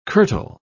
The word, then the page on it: curtal: British English